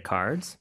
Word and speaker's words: cards: Do you accept credit cards? – 네, accept의 마지막 T 발음 살짝 해주는 거. 그 다음에 credit, CR 발음. 그 다음에 cards의 마지막 Z, 요 발음 잘 기억해 주시면서 연습 많이 해주시면 돼요.